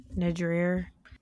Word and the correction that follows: nidra: Yoga, yoga nidra. Nidra. – Yeah, I have a lot of trouble pronouncing words. – Oh my God. – I don't want to go back to the Sister Schubert situation, but I did yoga nidir last week. Is that, wait, did I say that right? – It's yoga nidra.